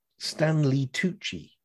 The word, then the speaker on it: Stanley Tucci: As he says himself, and it rhymes with Gucci, Stanley Tucci, I'm Italian on both sides.